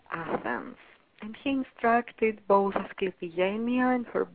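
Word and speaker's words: Asclepigenia: I'll say it one more time, the Greek way, Asclepienia.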